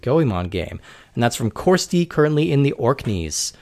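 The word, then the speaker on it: Ciorstaidh: It's C I O R S T A I D H, and it's pronounced like Corsti.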